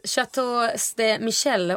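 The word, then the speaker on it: Château Ste. Michelle: Chateau de Michel, om jag uttalade rätt. Visst är det? – Absolut.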